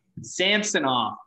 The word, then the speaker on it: Samsonov: Like Samsonov. – Samsonov.